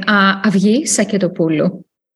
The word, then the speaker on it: Avgi Saketopoulou: Avgi Saketopoulou.